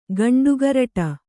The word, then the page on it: ಗಂಡುಗರಟ: kannada